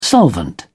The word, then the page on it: solvent: British English